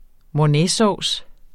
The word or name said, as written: mornaysauce